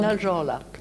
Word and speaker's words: Naldjorlak: And is that pronounced correctly, Najolak? – Najolak. – Najolak. – Yes, we don't pronounce a K at the end.